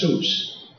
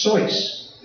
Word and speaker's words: Seuss: He said, well, it's my middle name, Theodore Blank Geisel, but of my mother's maiden name, and it's not pronounced Seuss. It's Seuss. It's German.